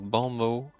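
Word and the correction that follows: bon mot: What was that weird pronunciation? It's Bon Mott.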